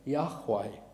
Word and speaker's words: Yahweh: We pronounce the Hebrew word Yahweh.